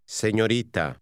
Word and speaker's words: señorita: Señorita. – Be careful with the R sound, which is different than in English.